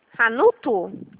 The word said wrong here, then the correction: Ranulpho: Ranulfo, RANULPHO. – PHO? – Isso, Prata.